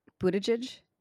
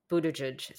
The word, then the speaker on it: Buttigieg: Although it does actually have to do with, I always mispronounce his name, Pete Buttigieg. – Buttigieg, thank you.